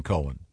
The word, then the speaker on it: Cohen: Cohen. Yeah, you. – This gets confusing because... – No, not when it's spelled C-O-H-E-N. – Yeah, it's definitely Cohen.